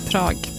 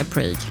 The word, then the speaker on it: Prag: Tydligen så uttalas Prag likadant på engelska som på svenska, typ. Svårt. Prag. Man vill ju säga Prague.